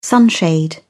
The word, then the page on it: sunshade: British English